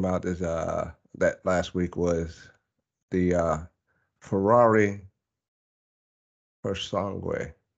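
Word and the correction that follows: Purosangue: Did, did I say it right? Pure, pure, pure sangue. Pure sangue, yeah.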